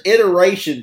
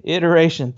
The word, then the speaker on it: iteration: The word is iteration. – Iteration. Thanks.